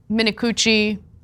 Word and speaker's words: Minicucci: Alaska Airlines CEO Ben Minicucci, that is his name, that is how you pronounce it, we did double check, is furious with Boeing, which manufactures all of the planes in Alaska Airlines' fleet.